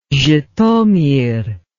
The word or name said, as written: zhitomir